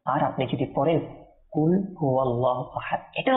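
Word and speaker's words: কুল (قل): বুঝার জন্য একটি উদাহরণ দিচ্ছি, যেমন কি মনে করেন আমরা সবাই ক্লাসে কি পড়ি, কুল না, কুল কফ দিয়ে, তো কুল মানে হে হাবিব আপনি বলুন, কুল কফ দিয়ে হে হাবিব আপনি বলুন। কিন্তু আপনি এটাকে যদি সঠিক উচ্চারণ না করে আপনি যদি বলেন কুল কুল অর্থাৎ কাফ দিয়ে কুল, তখন কি হবে জানেন? তখন হবে আপনি খান।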